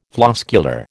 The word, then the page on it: floscular: American English